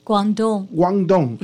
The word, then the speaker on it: Guangdong: Guangdong.